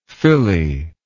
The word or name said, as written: Philly